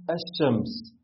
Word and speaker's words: الشمس: زي كلمة الشمس، فعلا ما سمعناش اللام وحرف الشين نطقناه مشدد.